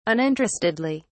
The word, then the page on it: uninterestedly: British English